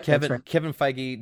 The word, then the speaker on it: Feige: And I want to say that like Kevin Feige, I hope I'm saying his name right. – Yep. – Kevin Feige,